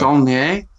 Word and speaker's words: Saulnier: I'll move on to A Murder Party by Jeremy, I don't know how to even bother pronouncing it. Oh, Saulnier. – Yeah, there you go. – Saulnier.